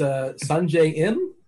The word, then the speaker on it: Sungjae Im: Somebody who was overpriced, at least last week, playing results, was this Sungjae Im.